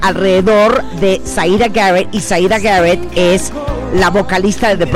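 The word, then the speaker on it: Siedah Garrett: Así es, y bueno, ya extrañaba la pronunciación perfecta que tienes, porque sí es Zaida Garrett, tienes toda la razón.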